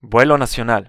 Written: vuelo nacional

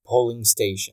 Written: polling station

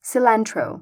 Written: cilantrou

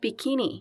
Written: bikini

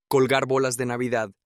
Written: colgar bolas de Navidad

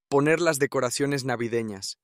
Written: poner las decoraciones navidenas

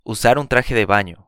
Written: usar un traje de baño

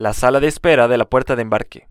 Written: la sala de espera de la puerta de embarque

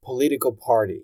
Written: political party

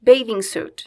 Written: bathing suit

